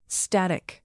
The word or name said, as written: static